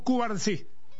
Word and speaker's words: Cubarsí: Habla Pau Cubars, ¿cómo es que se pronuncia? – Pau Cubars, sí. – Yes. Pau Cubarsí.